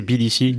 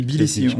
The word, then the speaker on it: Tbilissi: Bon, ouais, t'as l'air de mieux le prononcer que moi, c'est quoi? – C'est Tbilissi. – Tbilissi, on dit pas le T. – On prononce pas le T, je crois.